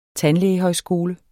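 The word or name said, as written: tandlægehøjskole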